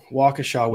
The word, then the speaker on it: Waukesha: Waukesha, Wisconsin. This case is this one's going to mess with the patience level that I've got here. So there was a Christmas parade that took place in Waukesha,